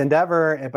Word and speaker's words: NDVR: So it's Endeavor, but it's spelled NDVR.